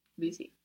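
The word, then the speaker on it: bizi: Busy. – Busy, good, with the accent on the end. Like Benny, Seni, busy.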